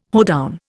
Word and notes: hoedown: Indian

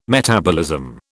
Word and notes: metabolism: British English

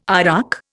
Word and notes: arak: Indian

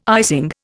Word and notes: icing: Indian

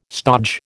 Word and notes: stodge: American English

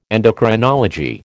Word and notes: endocrinology: American English

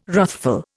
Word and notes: wrathful: Indian

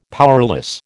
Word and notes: powerless: American English

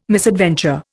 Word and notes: misadventure: Indian

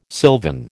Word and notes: sylvan: American English

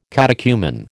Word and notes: catechumen: American English

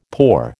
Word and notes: poor: American English

